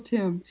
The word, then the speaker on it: Tymn: They're older books, but I recommend anything that Michael Tymn, and his name is spelled T-Y-M-N, with there's an N. It's like him. It rhymes with him, Michael Tymn.